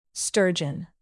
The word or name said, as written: sturgeon